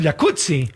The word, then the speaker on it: jacuzzi: Entonces deberíamos pronunciar el jacuzzi. – Exacto. – Como pizza. Como Rampazzo, como Claudia Rampazzo.